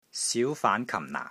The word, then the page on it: SIU2 FAAN2 KAM4 NAA4: Cantonese